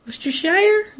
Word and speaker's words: Worcestershire: Worcestershire. Worcestershire? Worcestershire? Whatever. It's got like 3 syllables. I know it does. And you always tell me it doesn't. But it's got to, because look at it. – It's Worcestershire.